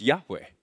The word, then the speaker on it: Yahweh: YHWH, which is pronounced Yahweh.